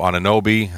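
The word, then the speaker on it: Anunoby: Also, Ananobi, that's how you pronounce his name, from Toronto.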